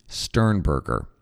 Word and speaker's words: Sternberger: I'm going to use it until it is in my brain that it's a hard G. Sternberger.